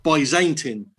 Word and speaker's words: Byzantine: First thing, Dave, there's been some research done, and apparently the word Byzantine, the A in the middle and the E is a split digraph, so it should actually be pronounced Byzantine.